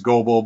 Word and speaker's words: Goebel: I have the same thing, because my last name is Goebel, but there's a silent E in there.